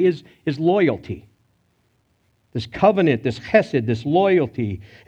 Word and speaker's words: chesed: You may see it spelled H-E-S-E-D or C-H-E-S-E-D, which is designed to be pronounced chesed, right?